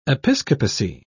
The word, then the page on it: episcopacy: British English